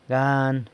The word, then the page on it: gan: sanlucasquiavini